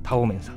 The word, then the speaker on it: 道麺: え漢字でね、え道路の道道と書いてそれから麺類の麺ですね、え道の道麺と書いてタオメンさんでございますえー、